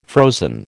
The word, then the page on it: frozen: American English